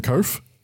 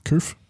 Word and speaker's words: Kouf: Actually written by Jim Koof. I think that's how it's pronounced. K-O-U-F. Koof. Yep. Koof.